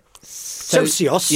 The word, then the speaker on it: Socios: Socios.